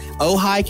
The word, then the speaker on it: Ojai: Uh, he's also a pastor in I'm gonna I don't want to butcher the name of this city in, in beautiful California, Ojai, California. Did I get this right? – You got it. You got it exactly.